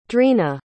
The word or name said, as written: dreena